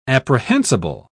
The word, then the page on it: apprehensible: British English